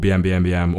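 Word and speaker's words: bmbmbm: or Boom Boom Boom. Do you know how it's pronounced? – I always say BM BM BM, but now you said Boom Boom Boom, that makes more sense to me.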